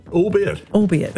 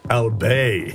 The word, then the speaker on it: albeit: Albeit. And thought it was pronounced French style, albay. That's so good. I first said it correctly in my late 20s when Kim Hill said it.